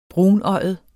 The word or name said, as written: brunøjet